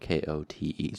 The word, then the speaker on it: Kote: Now, Kote is spelled K O T E, so it's not like the coat you would wear, but Kote. So yeah, they were almost surprised to see Kote standing there.